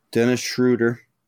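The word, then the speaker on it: Schröder: Dennis Schroeder.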